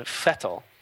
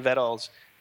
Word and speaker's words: Vettel: And she informed us that Sebastian's last name is pronounced Sebastian Vettel, because in Germany, the V's are pronounced with F's.